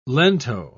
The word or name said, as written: lento